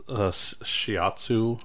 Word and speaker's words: Shiatsu: Shiatsu.